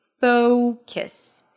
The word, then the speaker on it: focus: Focus. Then you shift to a K sound, a schwa, and an S. Kiss. Kiss. All right? So again, although the letter U is there, it is not pronounced. It's a schwa. It's reduced. Kiss.